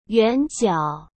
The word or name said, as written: yuanjiao